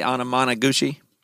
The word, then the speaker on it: Anamanaguchi: Your band am I saying it right if I say Anamanaguchi? – Okay. First try. Got it.